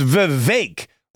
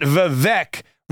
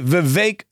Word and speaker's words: Vivek: Number four, when somebody mispronounces Vivek Ramaswamy, and remember, it is Vivek rhymes with cake, not Vivek rhymes with kek. Is that even a word? Rhymes with bleck. Okay? Vivek rhymes with cake.